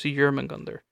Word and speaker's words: Jörmungandr: So it's Jörmungandr.